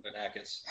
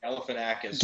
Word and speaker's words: Galifianakis: Galifianakis. – Who? – He's, Galifianakis. – Galifianakis.